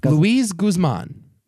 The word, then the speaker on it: Luis Guzman: Luis Guzman.